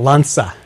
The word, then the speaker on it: Lança: Now, what is it? Lance? I've forgotten how you say it. – Yeah, Lança.